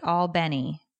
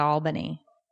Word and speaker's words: Albany: Oh, somebody's like, you pronounce it all Benny, not Albany.